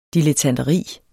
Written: dilettanteri

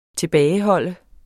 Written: tilbageholde

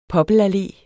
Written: poppelallé

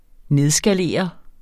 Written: nedskalere

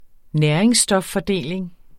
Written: næringsstoffordeling

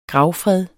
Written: gravfred

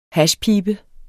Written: hashpibe

